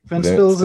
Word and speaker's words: Ventspils: It looks like Ventspils, but I know that it can't be right. – It's as simple as it is spelled. – Really? – Yeah. – Wow. Okay. Ventspils it is.